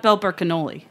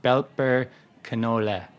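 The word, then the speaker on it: Belper Knolle: Not Belper cannoli. – Not cannoli. So German's a very phonetic language. And as far as I know, E's are not pronounced like they are in English. They're like eh sounding. So it's like Belper canola.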